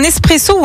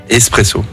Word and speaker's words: espresso: Alors, tiens, est-ce que tu dis un espresso ou un expresso? – Pour le café? – Oui. Ouais. – Espresso. – Espresso, ouais. Étymologiquement, on ne prononce pas le X. L'origine du mot vient de l'italien esprimere.